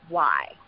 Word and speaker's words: Lindsay: I will say my name is spelled with an A. So when you hear me say it's Lindsay.